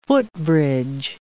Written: footbridge

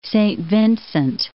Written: Saint Vincent

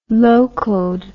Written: locoed

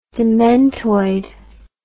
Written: dementoid

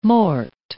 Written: mort

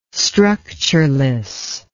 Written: structureless

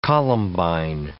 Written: columbine

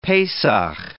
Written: Pesach

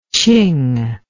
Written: Qing

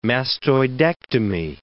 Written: mastoidectomy

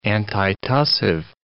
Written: antitussive

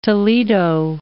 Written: Toledo

